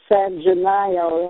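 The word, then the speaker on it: San Gennaro: And every feast day of San Gennaio, as it's pronounced in Italian,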